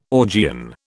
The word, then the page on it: Augean: British English